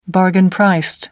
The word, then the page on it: bargain-priced: American English